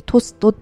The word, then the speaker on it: TOAST: はい、 TOAST でトストって読むんですね。